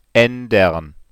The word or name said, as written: aendern